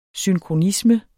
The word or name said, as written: synkronisme